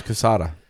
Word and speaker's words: Quesada: Pronounce Quesada. You said it right.